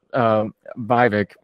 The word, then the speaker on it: Vivek: What we're going to criticize the other people, but people like Haley and DeSantis, you know, even Vivek rhymes with fake, rhymes with cake.